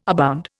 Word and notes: abound: Indian

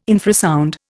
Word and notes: infrasound: Indian

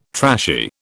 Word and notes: trashy: British English

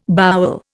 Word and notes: bowel: Indian

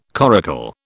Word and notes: coracle: British English

